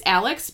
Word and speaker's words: Aleix: And so I looked it up, and in 2016, I think it's Alex, but it's spelled A-L-E-I-X, Segura is his name, and he held his breath underwater for 24 minutes and 3 seconds.